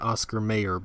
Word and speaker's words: Oscar Mayer: And everyone pronounces it Oscar Mayer, by the way.